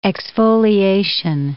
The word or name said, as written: exfoliation